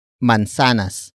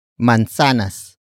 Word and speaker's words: mansanas: The next word is ang, which is spelled as A-N-G. It is the focus marker that introduces the main topic of the sentence. And that is mansanas. Mansanas. Mansanas. Mansanas is spelled as M-A-N-S-A-N-A-S.